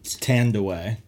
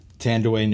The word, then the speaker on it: Thandiwe: Tandaway. – Tandaway Newton. I totally missed this moment. – Spelled T-H-A-N-D-I-W-E. Tandaway Newton reveals the correct spelling and pronunciation of her name.